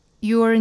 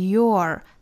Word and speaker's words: you're: You're never really done for, as long as you've got a good story. And someone to tell it to. – 好，我们首先看 you 和 are 的缩约读成 your, your, 注意尾部的有一个小小的卷舌 r 的发音。